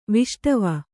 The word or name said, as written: ವಿಷ್ಟವ